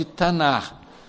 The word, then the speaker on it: Tanakh: Mas os judeus também chamam o Antigo Testamento de Tanar, como nós escrevemos com CH no fim, Tanakh, né? Mas eles, é uma pronúncia de H.